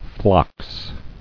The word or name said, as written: Phlox